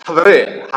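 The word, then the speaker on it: Haver (חבר): So the word is spelled C-H-A-V-E-R. I was like, Chaver, right? It's Haver, Haver. You know, when you speak Hebrew, Haver, right? So Haver.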